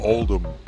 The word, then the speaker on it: Oldham: Oldham. – Without the H. Pronunciation.